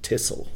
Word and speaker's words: Texel: In Holland they pronounce it Texel, the Texel Islands off the coast of the Netherlands.